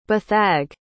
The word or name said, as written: beathag